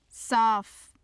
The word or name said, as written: saaf